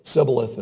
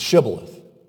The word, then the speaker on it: Shibboleth: That's what's going on here with Sibboleth and Shibboleth. It's Shibboleth.